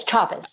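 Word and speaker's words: Chávez: Yes, Chavez. – Okay. – A lot of people say Chavez, but it's Chavez.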